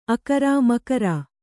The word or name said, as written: ಅಕ್ಕರ